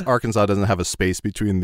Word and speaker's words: Arkansas: and Arkansas should just be Arkansas.